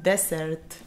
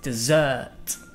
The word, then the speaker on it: dessert: Dicen desert. – The correct pronunciation is con el emphasis en la última sílaba. Entonces dirías dessert. Dessert, okay? – Yeah, would you like any desserts?